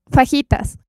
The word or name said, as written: Fajitas